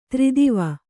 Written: ಧೀರತ್ವ

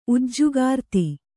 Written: ಉಜ್ಜುಗಾರ್ತಿ

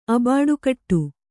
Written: ಅಬಾಡುಕಟ್ಟು

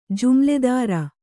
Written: ಜುಮ್ಲೆದಾರ

